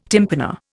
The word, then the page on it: tympana: Indian